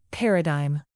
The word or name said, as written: paradigm